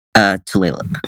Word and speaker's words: Tulalip: Tulalip. – Tulalip.